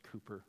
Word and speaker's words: Cowper: It's in the hymnal, it looks like Cowper, but it's pronounced Cooper.